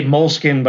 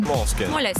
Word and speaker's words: Moleskine: Evernote, you know, I always say Moleskine, but apparently that's not how you pronounce Moleskine. – Moleskine.